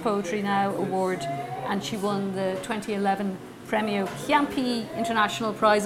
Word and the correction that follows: Ciampi: Am I saying that right? Premio Something Chiampi? Piero Ciampi.